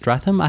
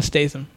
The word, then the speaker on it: Statham: Is it Stratham? How do you pronounce Statham. – His last name? Statham. – It's Statha.